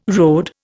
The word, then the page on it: RD: Indian